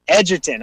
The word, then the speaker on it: Egerton: The cast includes major Hollywood stars like James McAvoy, Taron Egerton. I probably said his name wrong, right? Edgerton, okay.